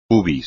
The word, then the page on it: pubis: espagnol